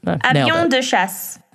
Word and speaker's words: Avions de Chasse: Close. Avions de Chasse. – Which is the French term for fighter jets, but also like a colloquialism to designate an incredibly hot chick, quote unquote.